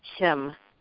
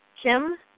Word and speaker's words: Kim: Oh, hi, my name's Kim. – Hi, would you spell that for me? – Kim, J-I-M. – J-I-M, hi, Jim. – K-I-M. – K-I-M, Kim.